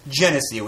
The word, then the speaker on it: Genesee: It cost like $2, and when I was in high school, I thought it was hilarious because it was called Genesee, which rhymes with Hennessy.